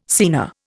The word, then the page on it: scena: Indian